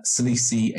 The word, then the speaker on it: Salisi: Well, Salisi is spelled S-A-L-I-S-I, Salisi.